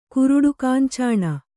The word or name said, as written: ಕುರುಡುಕಾಂಚಾಣ